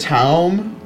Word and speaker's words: time: she actually pronounces it Taum? – Yeah, I, I hadn't heard.